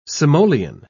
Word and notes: simoleon: British English

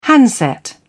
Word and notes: handset: British English

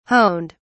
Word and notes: honed: British English

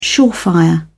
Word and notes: surefire: British English

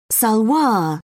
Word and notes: salwar: British English

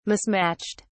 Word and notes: mismatched: British English

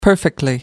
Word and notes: perfectly: American English

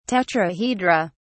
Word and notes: tetrahedra: British English

